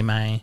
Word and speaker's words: me: He wanted it the pronunciation needed to be May, I think, to probably to rhyme with the aforementioned line or with the previous line. – Mm-hmm. – And so he was like, I need you to sing it like May.